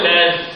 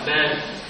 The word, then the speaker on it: lên: Năm cánh hoa tròn và nhị phô, chữ này là chữ nổi lên chứ không phải nổi lên, nhưng mà tại vì cái ông Trần Văn Lê có lẽ là ông người Thái Bình cho nên đọc là nên.